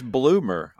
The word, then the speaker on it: Blumer: I was like, is it Blumer or is it Bloomer? – Yeah. Everybody says Bloomer. I'm probably pronouncing my name wrong.